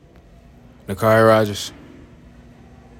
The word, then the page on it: NaQuari Rogers: nuh CAH REE rah JERS